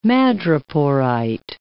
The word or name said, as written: madreporite